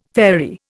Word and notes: fairy: Indian

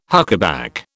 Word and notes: huckaback: British English

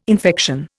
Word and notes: infection: Indian

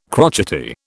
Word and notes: crotchety: British English

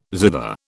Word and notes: zither: British English

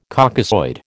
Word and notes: Caucasoid: American English

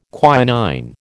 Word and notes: quinine: American English

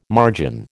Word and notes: margin: American English

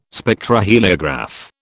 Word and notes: Spectroheliograph: British English

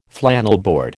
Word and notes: flannelboard: American English

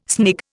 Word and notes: snig: Indian

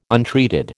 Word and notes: untreated: American English

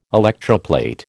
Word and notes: electroplate: American English

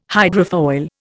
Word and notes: hydrofoil: Indian

